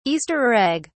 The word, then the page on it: easter egg: British English